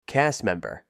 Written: cast member

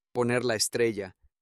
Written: poner la estrella

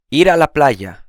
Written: ir a la playa

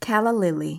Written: calla lily

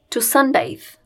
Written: to sunbathe

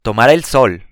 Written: tomar el sol

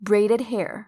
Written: braided hair

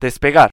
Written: despegar

